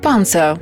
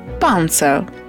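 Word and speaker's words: Panzer: Панцер. Танк. Вимовляйте це слово повільно, намагаючись вимовляти звуки зі справжнім німецьким акцентом. Із твердою «п» попереду, м'якою «р» наприкінці і вимовляючи «з» як впевнене «ц» всередині. Панцер.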